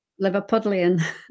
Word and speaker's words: Liverpudlian: it is a uniquely Liverpoolian. Am I saying that right, or is it Liverpudlian? – Liverpudlian. – There you go. What she said.